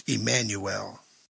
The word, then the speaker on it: Immanuel: In English, Immanuel is pronounced Emmanuel.